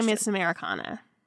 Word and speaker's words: Miss Americana: That's Miss Americana.